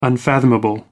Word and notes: unfathomable: American English

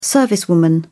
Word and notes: servicewoman: British English

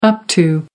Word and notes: up to: American English

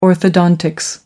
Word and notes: orthodontics: American English

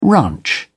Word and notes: ranch: British English

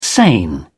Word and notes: sane: British English

